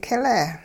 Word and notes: kileh: hupa

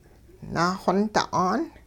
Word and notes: naaxonta'aan: hupa

